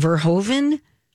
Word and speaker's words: Verhoeven: Verhoeven? – Nailed it.